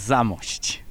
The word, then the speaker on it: Zamość: But we've come to the beautiful city of, my last chance to mispronounce something, Zamość. – It's Zamość.